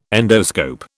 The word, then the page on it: endoscope: British English